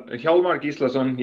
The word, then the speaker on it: Hjálmar Gíslason: How was I on the pronunciation of your name? – Yeah, so Hjálmar Gíslason, you were pretty close. – And where's the name coming from? Where are you based? – So the name is Icelandic.